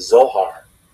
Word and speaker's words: Zohar: And the kind of most famous text in Kabbalistic tradition is called the Zohar, which is spelled Z O H A R.